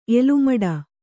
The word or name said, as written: ಎಲುಮಡ